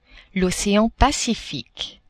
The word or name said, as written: ocean Pacifique